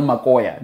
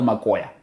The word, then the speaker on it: Makoya: We call it the Real Makoya. Now, the Brits say Real McCoy. That's not how you say it. It's the Real Makoya.